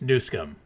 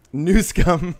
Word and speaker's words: Newsom: You mispronounced his name. It's Newsom. – Newsom.